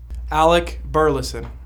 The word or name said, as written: Alec Burleson